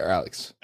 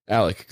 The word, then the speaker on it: Alek: And is it Alec or Alex? – Alec, good. – Spelled with a K like a fucking idiot.